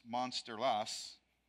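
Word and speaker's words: Mönsterås: And as the story goes, he was walking home from a revival service in this little Swedish town, I'll try to pronounce this, Monsterlas. That's actually how it sounds, yeah, okay.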